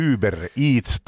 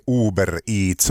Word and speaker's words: Uber Eats: Se on semmoinen, kun kirjoitetaan Uber Eats. – Just näin. – Ja lausutaan Uber Eats tai Uber Eats.